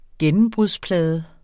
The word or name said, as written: gennembrudsplade